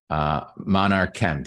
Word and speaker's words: Monarchchem: Through the fortuitous chain of events, Patrick joined his college roommate and a lovable retired 75-year-old chemist founded their company, Monarchchem.